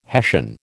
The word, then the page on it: hessian: American English